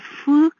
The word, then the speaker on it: Phước: My middle name is Thị Minh, and Phước, spelled P-H-U-O-C, is my first name, which is often misspelled as P-H-O-U-C, and pronounced in many interesting ways, such as pho, which means Vietnamese beef noodle soup, phoc, like a fox, or even phu, like crazy in French.